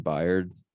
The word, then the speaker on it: Byard: I mean, the rest of their defense, I feel like they got solid players like Jeffrey Simmons, Rashawn Evans, and Kevin Byard. Never know how to say his last name. – I think you got it.